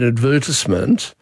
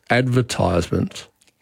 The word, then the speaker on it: advertisement: But the thing that we advertise, I call an advertisement. Another of our producers routinely says advertisement. – Yes, and that's a more recent pronunciation, but still around for a couple of hundred years, and it would come about because of the verb advertise.